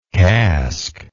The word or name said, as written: casklike